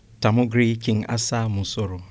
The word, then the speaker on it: Tamugri King Asa Musoro: Did I say it right? – Tamugri King Asa Musoro.